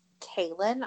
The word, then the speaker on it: Kahlen: So the main protagonist is Kaylin, I believe is how you pronounce her name. It's spelled K-A-H-L-E-N. – That's my cousin's name, and yes, Kaylin. Well, hers is nice. K-A-H-L-A-N, and it's Kaylin.